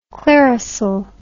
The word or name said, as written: clearasil